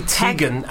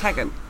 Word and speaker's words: Teigen: Yeah, but even because Chrissy Teigen, as everyone calls her, it's Tegan.